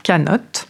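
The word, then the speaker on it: canot: En réalité, ces nomades de la mer passent plus de temps sur leurs canots, qui se prononce d'ailleurs canotte, qu'à l'intérieur de leur maison.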